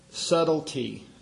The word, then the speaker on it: subtlety: This word in verse 4 that I read, subtlety, I think there's two different ways to pronounce that word, but the B is silent. It's subtlety, subtlety.